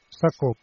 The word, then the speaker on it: Sacko: Non so se la pronuncia. Sumaila Sacco. Sacco, perfetto, grazie.